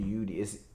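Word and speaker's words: Eudoxie: I think her last name, I would say, is Zwee. Or no, Yudi. Yudi. I don't think the X is pronounced. I think it would be Yudi. It's E U D O X I E is her first name.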